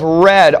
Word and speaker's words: Wrede: That phrase was coined by a guy by the name of Red, but it's spelled W-R-E-D-E, at the end of the 19th century.